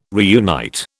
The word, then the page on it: reunite: British English